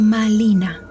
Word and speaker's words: Malina: Ese sonido no existía, por lo que el nombre de Marina lo debió de pronunciar como Malina con L. Es decir, me llamo Malina.